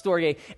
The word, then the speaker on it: storge: It's pronounced storge.